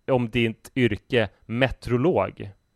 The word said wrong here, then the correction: meteorolog: Kan du säga hur det heter egentligen? Meteorolog. Hur många skulle du säga säger, säger uttalar alla de här eh, vokalerna? – Eh, inte så många.